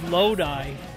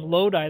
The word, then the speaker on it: Lodi: how often do you hear the name Lodi pronounced incorrectly? – Lodi. – Lodi, yeah. – That's what we hear is Lodi.